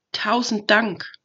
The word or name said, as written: tausend dank